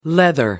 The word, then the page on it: leather: American English